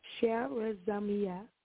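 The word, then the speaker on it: charizomai (χαρίζομαι): Divine forgiveness, church, is an extension of grace as seen in the Greek word, and it's spelled, and I'll pronounce it for you, it's charizomai, and it's spelled charizomai, which is translated forgive.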